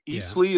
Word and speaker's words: Eastleigh: They beat Eastleigh. Is that how you pronounce that? – That is it.